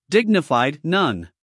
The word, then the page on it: dignified: ˈdɪɡnɪfaɪd